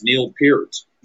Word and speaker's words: Peart: It's Neil Peart.